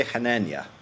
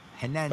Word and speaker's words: Hanania: How do you say it? – I say Hanania. – Hanania. – But I defer to whatever people want to say.